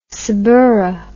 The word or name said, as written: saburra